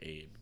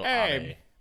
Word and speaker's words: Abe: No, it's definitely not pronounced like Abe, but Shinzo Abe.